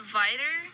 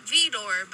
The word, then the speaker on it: Vidor: It's pronounced Vidor, V-I-D-O-R, Vidor, like... It's not really like anything. Most people who pass through are like Vidor, but everyone who lives there calls it Vidor.